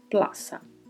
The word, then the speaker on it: plaza: Plaza é escrito com a letra seta letra Z, mas pronunciamos com som de S, plaça.